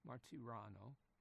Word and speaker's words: Martirano: And Salvatore Martino, Martiano, keep mispronouncing his name, Salvatore Martirano, Salvatore Martirano, Roman Catholic of Italian extraction.